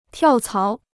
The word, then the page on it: 跳槽: tiào cáo